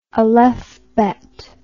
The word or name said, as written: alephbet